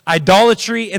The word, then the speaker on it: idolatry: That even when there is idolatry, I say that wrong, idolatry in the land, there's another syllable in there, isn't there?